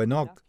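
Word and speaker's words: Hanok (חנוך): Se remonta al libro de Génesis, donde hemos hablado de Hanok o Enoc, según como pronuncies su nombre. En hebreo es Hanok.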